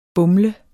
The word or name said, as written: BUMLEDES